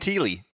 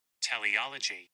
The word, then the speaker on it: teleology: T-E-L-E-O-L-O-G-Y. – Oh, you kept saying teely. – Teleology. – Right. – So I have a recording here. – You shut up. – Listen closely. – Teleology.